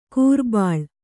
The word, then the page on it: ಕೂರ್ಬಾಳ್: kūrbāḷ